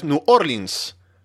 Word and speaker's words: New Orleans: Esto se dice New Orleans, New Orleans, ¿no? Pero es New Orleans. Así se pronuncia.